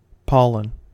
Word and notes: pollen: American English

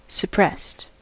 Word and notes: suppressed: American English

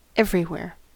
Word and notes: everywhere: American English

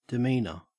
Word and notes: demeanour: Australian English

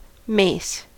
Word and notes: mace: American English

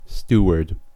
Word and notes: steward: American English

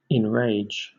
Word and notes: enrage: British English

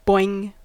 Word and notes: boing: American English

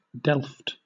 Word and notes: delft: British English

